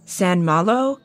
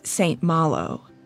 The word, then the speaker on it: St. Malo: Some online dictionaries pronounce the name of this camp with a French pronunciation like San Malo, like it's one word, but others say it like Malo. I'm going to pronounce it how I most commonly heard it, and that is St. Malo.